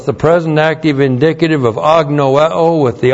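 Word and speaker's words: agnoeo (ἀγνοέω): And then we have agnoeo. Agnoeo. A-G-N-O-E long O.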